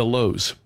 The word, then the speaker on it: Louhs: We are on Facebook, Twitter, Instagram, and YouTube, all under the headings of The Lows. And that last name is spelled quite uniquely. It's spelled L-O-U-H-S.